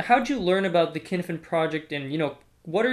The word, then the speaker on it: Cynefin: So, you know, we're here to talk about the Kinefin Project, and that's spelled C-Y-N-E-F-I-N.